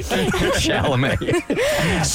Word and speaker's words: Chalamet: First of all, we need to say his name right. – Chalamet.